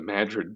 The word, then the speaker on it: Madrid: through the Madrigal pro or excuse me, the Madrid protocol, or why can't I Madrid. – speak? The Madrid Protocol. Sorry, I'm from Iowa. There is a town called Madrid, spelled the same exact way, or called Madrid, spelled the same exact way as Madrid.